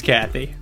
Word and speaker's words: Qathy: It's spelled Q-A-T-H-Y. – Yes. – Space Cathy. – Space Cathy.